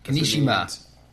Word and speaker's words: Kimishima: So, Tatsumi Kimishima, I think I pronounced that right. – Kimishima.